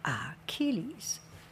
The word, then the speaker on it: Achilles: Achilles. A-C-C-H, pronounced key. A-C-H-I-L-L-E-S.